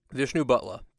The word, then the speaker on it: Vishnubhatla: It's Vishnu and then Brooklyn accent, Butler. – Okay. – Vishnubhatla.